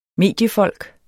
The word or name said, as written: mediefolk